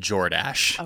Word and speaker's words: Jordache: It's pronounced Jordache.